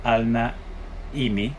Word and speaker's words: Alnaimi: Ella es Sabrina Alna Imi, si no pronuncie mal.